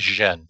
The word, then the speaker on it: Chen: C-H-E-N is Zhen, so it'd be Chen Zhen.